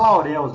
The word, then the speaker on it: Laureus: O prêmio Lauro, é Lourô? Como é que fala? Lauros? – Ah, Lourdes? Eu falo Laureus mesmo, é azar.